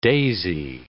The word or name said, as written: daisy